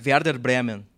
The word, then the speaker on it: Werder Bremen: no, vamos no Werder Bremen primeiro, qual a pronúncia que tu fez? Werder Bremen. – E, de... e emprestado pelo? Eintracht Frankfurt. Que fantástico.